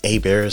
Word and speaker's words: Hebert: So, A-Bear Specialty Meat is spelt like Hebert.